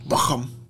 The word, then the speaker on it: Bochum: You have to say it like you're German. Bochum.